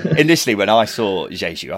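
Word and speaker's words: Diédhiou: Oh sorry, Diadu. – So for anyone who hasn't seen it yet, Chris Kamara, in his infinite wisdom, decided to pronounce Famara Jeju as Diadu.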